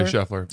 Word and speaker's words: Scheffler: and the guy who ended up winning was Schaefer. Is that how you say it? – Scotty Scheffler.